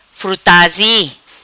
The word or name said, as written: Frutazi